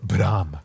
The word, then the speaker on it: Blame!: Yeah, I want to talk about the pretty thing that we were all looking at online this week, namely the latest trailer for Bram. Which is how they say it in the preview. But Blame!